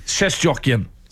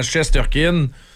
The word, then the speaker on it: Shesterkin: Shesterkin. C'est comme ça qu'il faudrait le dire. Alors qu'on l'américanise en disant Shesterkin parce que c'est plus facile.